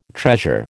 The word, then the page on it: treasure: American English